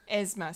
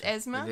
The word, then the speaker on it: Yzma: How do I say her name? Yzma. From Emperor's New Groove. – Esma. – See, I haven't watched the movie in a long time, so I don't remember. So it's Esma.